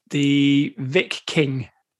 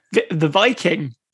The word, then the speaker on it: Viking: The Vic King. – Yeah, go on. Now say that properly. – The Viking.